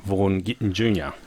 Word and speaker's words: Vaughn Gittin Jr.: And as I said, Vaughn Gittin Jr. I said that correctly.